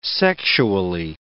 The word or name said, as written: sexually